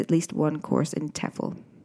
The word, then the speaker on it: TEFL: So what's a TEFL course? T-E-F-L, pronounced TEFL for short, stands for Teaching English as a Foreign Language.